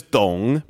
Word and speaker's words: Dong: I don't know if you've really like Mason's not my first name, you know, my middle name is Dong. But it's spelled D-O-N-G, so everyone thinks it's Dong.